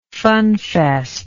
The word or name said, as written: funfest